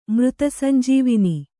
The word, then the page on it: ಮೃತಸಂಜೀವಿನಿ: mřta sanjīvini